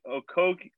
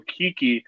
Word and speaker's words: Okeke: Michael Carter-Williams, Ennis, Fultz, Isaac, Okeke, I still said it wrong, Okeke, Porter, and Ross are all out.